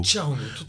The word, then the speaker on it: charango: Donc c'est Jay au Carango et là au charango. – charango au charango, excuse-moi.